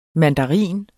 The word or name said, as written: MANDARINEN